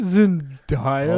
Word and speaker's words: Zendaya: It's actually Zendaya. – Hold on, wait. Let's get it slow. – Zendaya. – It says it right there, day.